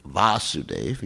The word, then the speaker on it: Vasudev: Chaturvyuha means Vasudev, the original Vasudev, Vasudev, you know, with a long A, is Krishna.